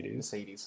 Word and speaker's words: Mercedes: Mercedes, sorry. Yeah, I throw out the E a bit more.